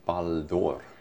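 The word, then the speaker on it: Ballon d'Or: Baldur heter det på franska. – Baldur. – Ja, precis. Det är en bättre uttal.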